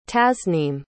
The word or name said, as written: tasnim